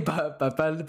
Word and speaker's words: Papel: I was saying Papel, Papa. – Papel, yeah.